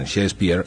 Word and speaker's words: Shakespeare: Este año toca leer a Shakespeare. – William Shakespeare, los entendidos pronuncian Shakespeare, nació el 23 de abril de 1564 en Stratford-upon-Avon, una pequeña población situada a unos 160 kilómetros al norte de Londres.